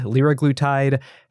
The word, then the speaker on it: liraglutide: Are we pronouncing semaglutide, liraglutide? How do you pronounce it at Novo Nordisk, Lotte? – I think you're doing pretty good.